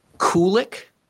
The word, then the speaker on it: Kulik: Drake's last name is pronounced Kulik, like Cool Whip, Kulik.